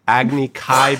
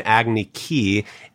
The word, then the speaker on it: Agni Kai: Agni Kai became Agni Ki.